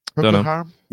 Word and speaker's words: Procol Harum: A Whiter Shade of Pale by Procol Harum. Is that right? Am I saying that right? – Procol Harum? – Yeah, that's the one.